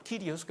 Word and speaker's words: Kyrios: Mira la palabra Señor del griego Kyrios, o Kyrios pronuncian algunos, pero Kyrios creo que es la mejor pronunciación.